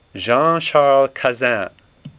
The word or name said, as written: Cazin